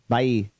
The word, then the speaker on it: Baha'i: Today we are going to take a look at the Baha'i, I think it's pronounced, Baha'i faith. – Baha'i.